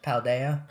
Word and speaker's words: Paldea: Paldea. – Paldea is the most recent one, because that's, again, recent.